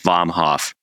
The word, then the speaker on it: Vomhoff: And I was like, it's pretty phonetic. It's just Vomhoff, V-O-M-H-O-F.